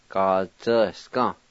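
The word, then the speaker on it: gatsvyasga: The A with the Y will be silent in this word. You just say the Y sound like this. Ga-zi-ya-s-ga. Ga-zi-ska.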